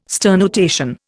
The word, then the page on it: sternutation: Indian